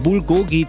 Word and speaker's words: Bulgogi: Fites, wenn ihr da seid, ich sage nur Bulgogi Cheesesteak. Habe ich das richtig ausgesprochen? – Korrekt.